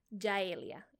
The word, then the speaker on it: Yaelia: which is Yaelia, and it's spelled Y-A-E-L-I-A, Yaelia.